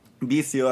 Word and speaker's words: vicio: But this is vicio mezcal, and this is really the reason why I'm here now. – Could you spell that? – Vicio as in vice. V-I-C-I-O.